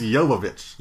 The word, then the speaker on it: Jovovich: It's not Jovovich? – No, it's... – I really thought it was. – It's Jovovich. – Jovovich. – Yeah, Mila Jovovich. – Jovovich. Oh, so the J is... Jovovich? The J is a Y.